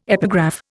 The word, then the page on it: epigraph: Indian